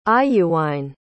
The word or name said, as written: iuwine